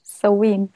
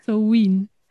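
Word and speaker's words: Samhain: Soween. – Soween.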